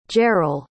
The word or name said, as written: Jeryl